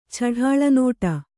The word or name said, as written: ಛಢಾಳನೋಟ